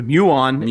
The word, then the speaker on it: Muon: I'm probably gonna butcher the name. Muon. – The Muon is. Yeah,